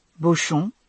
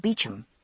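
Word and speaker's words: Beauchamp: He had, in fact, pronounced the name in correct French, though I had given it the common English pronunciation of Beecham.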